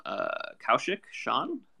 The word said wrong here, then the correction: Kaushik Shankar: Am I pronouncing that right? – Yeah, it's Kaushik Shankar.